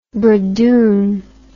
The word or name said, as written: bradoon